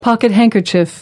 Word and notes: pocket-handkerchief: American English

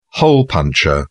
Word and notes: hole punch: British English

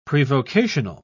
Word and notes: prevocational: British English

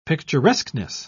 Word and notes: picturesqueness: British English

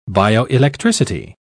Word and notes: bioelectricity: British English

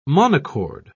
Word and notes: monochord: British English

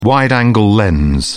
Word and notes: wide-angle lens: British English

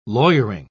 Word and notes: lawyering: British English

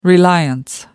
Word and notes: reliance: American English

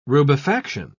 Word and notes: rubefaction: British English

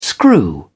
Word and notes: screw: British English

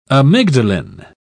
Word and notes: amygdalin: British English

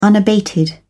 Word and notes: unabated: British English

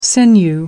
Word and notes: sinew: American English

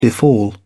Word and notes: befall: British English

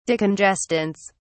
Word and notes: decongestants: British English

